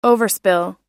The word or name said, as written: overspill